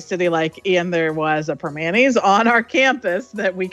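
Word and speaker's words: Primanti: and they always pronounce it the Permanente Brothers sandwich. Like, they make it sound like so formal and frou-frou. And let me tell you, in Pittsburgh, we have a heck of an accent and we always say Permanis. So that's how it's said, Permanis.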